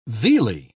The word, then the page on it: vealy: British English